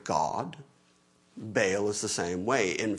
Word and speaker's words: Baal: The word Baal, which would be a closer to correct pronunciation, two syllables, Baal, usually when it's spelled out in English letters to try to carry the Hebrew into English, it'd be like B-A with an apostrophe and an A-L, so just see the two syllables in there.